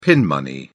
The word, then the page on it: pin money: British English